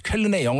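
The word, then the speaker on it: Köln: 이게 퀘른이에요. 퀘른. 퀘른의 영어식 표기. – 아, 이거 네. 어떻게 읽는 거예요? – 퀘른. – 아니, 퀘른이라고 읽으면 돼요? – 네네네. 아. 퀘른이라고 읽으면 되는데 얼마 전에 독일 다녀오셨다고 그래가지고.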